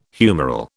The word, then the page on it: humoral: British English